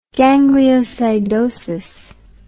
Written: gangliosidosis

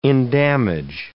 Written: endamage

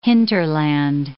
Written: hinterland

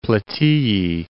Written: Plataeae

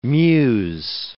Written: muse

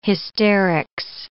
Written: hysterics

